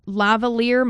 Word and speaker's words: lavalier: And she required a lavalier microphone.